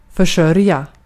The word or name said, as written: försörja